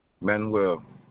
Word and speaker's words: Manuel: Manuel. – Can you spell that for me? – M-A-N-U-E-L.